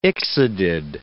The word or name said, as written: ixodid